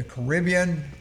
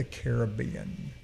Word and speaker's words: Caribbean: It's from the island of Trinidad and Tobago, located in the Caribbean, or for you folks that saying I'm pronouncing it wrong, the Caribbean.